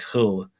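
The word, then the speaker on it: Hölle: But in German, it's H, O with an umlaut, the two dots over the O, and then L-L-E. So it's more like hull.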